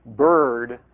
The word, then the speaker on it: bird: For example, in the word bird, the R sound is considered to be the vowel in that word. Okay, you really don't pronounce the I in bird. It's B-R-D in terms of kind of phonetic decomposition.